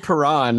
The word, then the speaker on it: Perron: who's back playing with Ryan O'Reilly and David Perrin. Are you laughing? I'm laughing at these pronunciations. It's Blay and it's David Perron.